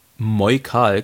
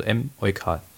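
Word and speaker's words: Em-eukal: Der Clou dabei, Moikal klang zu sehr nach Apotheke. Daher wurde das M geschrieben, wie es ausgesprochen wird. Also M. Eukal.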